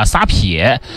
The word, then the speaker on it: 撒撇: 再往云南看去啊，在德宏、耿马、西双版纳等地有一道同样特立独行的美味，叫做撒撇啊，撒撇啊，读音和鳖是类似的啊。